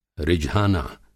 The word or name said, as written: रिझाना